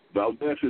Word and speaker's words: Valdes: And as always, Valdes is spelled with an S, not a Z.